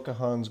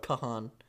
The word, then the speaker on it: Kahan: Yeah, and then second is Noah Kahan's Growing Sideways. – Stop saying it like that. That's fucking with me. – What? – Kahan. I know it's how it's spelled, but don't do that.